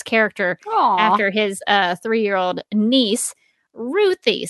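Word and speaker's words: Ruthye: And Tom King said, she's named after my 3-year-old niece, Ruthie. So that's how I pronounce it, but you be you.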